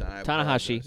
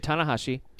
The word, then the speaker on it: Tanahashi: Tanahashi. Just say Tanahashi.